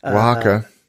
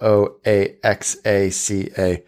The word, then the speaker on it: Oaxaca: There's no similarity in spelling, because they spell it W A H A C A, and it's spelled O A X A C A.